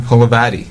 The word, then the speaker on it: Holovaty: I'm here in Chicago for Rails Conference, but I thought I'd take the opportunity to talk to a local developer, Adrian Holovaty. Did I say the right that time? – Holovaty, yes.